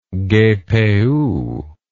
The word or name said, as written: gpu